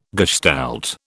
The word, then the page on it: gestalt: British English